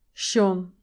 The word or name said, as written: shone